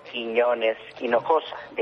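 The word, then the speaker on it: Quinones-Hinojosa: First of all, my complete name is Alfredo Quinones-Henojosa. The H is silent, and Quinones is my father's last name, and Hinojosa is my mother's maiden last name.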